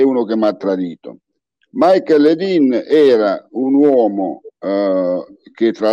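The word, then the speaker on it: Michael Ledeen: Ci racconti di questa vicenda di Michael Lidl affinché ne parlasse poi con Henry Kissinger, l'uomo... – Allora, ancora... Michael, sì, la pronuncia è detta Michael Ledin.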